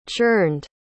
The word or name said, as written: churned